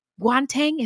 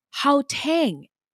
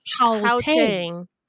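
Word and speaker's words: Gauteng: Guanteng is pronounced, we got this wrong, so thank you so much. It's pronounced Haotang. It actually has a more throaty H sound. – Huh. Haotang.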